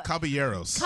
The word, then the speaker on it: Caballeros: The three Habaneros. Is that how you say it? – Habaneros. – The three plates of carbonara. – So three peppers, right? Isn't that what it is? – No. – Caballeros. – Caballeros. I apologize.